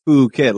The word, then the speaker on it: Phuket: Like Phuket, like they say, because if you read it, it's just fucking.